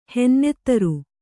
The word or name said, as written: ಹೆನ್ನೆತ್ತರು